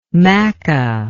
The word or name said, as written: Makkah